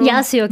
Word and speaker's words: Yazio: Yazio, ja, ja.